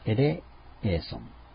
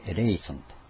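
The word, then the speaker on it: エ レ エ ーソ ン (ἐλέησον): で、これをギリシャ語で言えば、キュリエ、エレエーソン、メ。メっていうのは英語のミーですね。キュリエ、主よ。エレエーソン。これは、エレエーソン、アオリスト命令という文法的な用語でありますけれども、命令形なんですね。主よ、私を憐れんでくださいと。でこれが、まあ、ちょっと発音がしやすいように変えられて、キーリエ・エレイソンというようになってですね、ミサ曲に、あるいはミサ曲にですね、ずっと今日まで取り入れられて、非常に有名になったことはですね、主よ、憐れんでくださいと。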